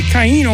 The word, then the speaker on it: Chaino: Although it's spelled Chaino, but Caino, and he's born in Philadelphia.